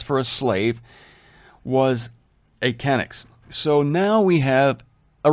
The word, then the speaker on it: chenix: It's spelled C-H-E-N-I-X, but it's pronounced kenix, and it's 1.5 pints by our standards.